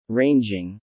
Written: ranging

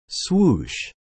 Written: Swoosh

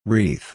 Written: wreath

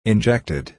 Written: injected

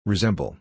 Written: resemble

